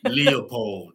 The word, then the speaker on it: Leopold: Leopold.